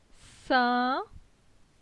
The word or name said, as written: Sin